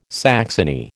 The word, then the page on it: saxony: American English